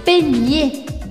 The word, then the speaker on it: peigner: Peigner la girafe. la girafe. Aqui vai ser ótimo porque vocês também vão poder treinar pronúncia ao dizer penhe, penhe, que significa pentear.